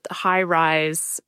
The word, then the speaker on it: HiRISE: I definitely recommend all listeners go check out High Rise. It's spelled H-I-R-I-S-E, High Rise.